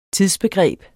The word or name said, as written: tidsbegreb